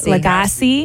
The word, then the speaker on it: Lagasse: Lagasse.